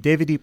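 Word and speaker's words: Devadip: So it's spelled D-E-V-A, like Deva, mm-hmm. D-I-P, Devadeep Orchestra.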